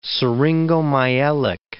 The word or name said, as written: syringomyelic